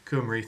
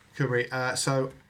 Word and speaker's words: Cymru: There's an article on the BBC about first Cymru. Oh no, I've walked myself into the Welsh pronunciation trap, haven't I? Cymru, thank you. You know better than me. Cymru.